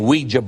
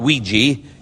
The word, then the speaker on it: Ouija: Have you ever thought about the name Ouija board? And it's not Ouija, it's Ouija board. O-I-J-A.